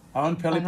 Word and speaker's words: Oenpelli: And you'll have to excuse my pronunciation of the name of the python, but that Appreciating Every Presence asks, have you ever seen the Oenpelli python? Is that the right pronunciation, or am I? – Indeed. – Yep. – Oenpelli python.